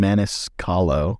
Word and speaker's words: Maniscalco: Sebastian Maniscalco. – What? Maniscalco.